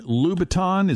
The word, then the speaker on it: Louboutin: Christian Louboutin, is that how you pronounce it? – Yeah.